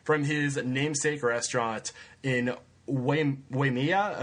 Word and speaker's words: Waimea: Also in uh, I'm pretty sure I said that right. – Waimea. Waimea, yes.